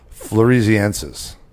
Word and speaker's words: floresiensis: Is that it? – Oh God, Cliff's gonna kill me. He's always telling me how to say it right because I've always said it wrong. When I read it, I'd say it in my head. – Yeah, wrong. Floresiensis.